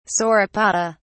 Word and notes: sauropoda: British English

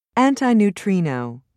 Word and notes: antineutrino: British English

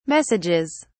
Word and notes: messages: British English

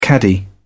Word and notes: caddy: British English

